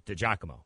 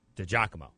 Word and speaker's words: DiGiacomo: Had to think about DiGiacomo. Accent on the first part there. Instead of Giacomo. DiGiacomo.